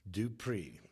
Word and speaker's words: Du Preez: Du Preez.